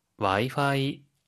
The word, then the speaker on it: Wi-Fi: Wi-Fi, Wi-Fi。 – 와이파이, 와이파이고요. – 와이어레스, 와이어리스, 와이어리스 무선이란 뜻입니다. 혹시 발음 팁 있을까요? – 와이파이는 F 발음이기 때문에 파가 아니라 화라고 발음해야 합니다.